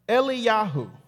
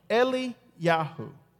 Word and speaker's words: Eliyahu: Elijah in Hebrew is pronounced Eliyahu. Eliyahu.